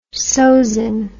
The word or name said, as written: sozin